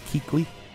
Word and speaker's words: Kuechly: Am I saying his name wrong? – Luke Kuechly? – I said it right. I nailed it. – Yes.